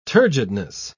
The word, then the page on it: turgidness: British English